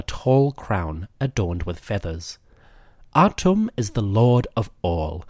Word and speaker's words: Atum: We call him Atum, but the Egyptian pronunciation might be closer to Temu, aka the one who is complete.